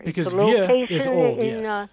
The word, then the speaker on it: vieux: I believe you were trying to pronounce V-I-E-U-X. – And that's correct. – And that is vieux.